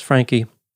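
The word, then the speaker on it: Franke: Some media sources pronounce her last name as Frankie.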